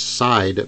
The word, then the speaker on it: Seid: It's C-A-R-R-I-E and then it's Side, but it's spelled S-E-I-D.